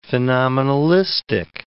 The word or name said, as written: phenomenalistic